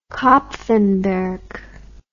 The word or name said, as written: Kapfenberg